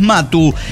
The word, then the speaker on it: Maatu: La pronunciación de esta empresa, este emprendimiento fabuloso gastronómico es Matu. – Matu. – O sea, simplemente así, pero, bueno, el significado es carbón en lengua yaqui, que es la lengua nativa de la zona, ¿no? Por lo tanto, se escribe de una manera diferente. M-A-A-T-U, ¿no?